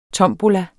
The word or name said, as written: tombola